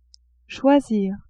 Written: choisir

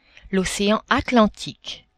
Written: ocean Atlantique

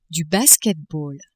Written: basket-ball

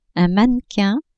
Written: mannequin

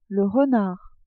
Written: renard

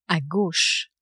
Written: a gauche

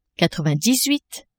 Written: quatre-vingt-dix-huit

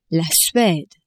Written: Suede

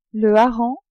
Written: hareng

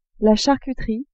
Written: charcuterie

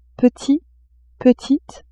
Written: petit-petite

